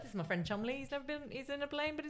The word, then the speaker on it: Cholmondeley: Now, here on, I'm going to be calling him Chumley. – Cool. – Because as much as his name very much is not spelled Chumley, apparently that's how it's pronounced.